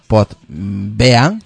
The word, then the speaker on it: Podbean: Pero pues eso, ya nos hemos trasladado a Podbean, se escribe. Voy a escribirlo ahí al, al grupo POT B E A N. Así se, así se pronuncia, pues así se escribe.